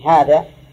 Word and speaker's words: هذا: لا ابن الحارث، لكن هم مثل الحارث ما يحطون فيها ألف، هذا ينطق في الألف ولا تكتب، مثل هذا أي هذا فيها ألف بين الها والزال، نطقا فيها ألف لكن كتابة لا.